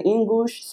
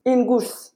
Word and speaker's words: Ingush: There's, you know, like official land issues right now between the Chechens and, how do you say, Ingush, just Ingush.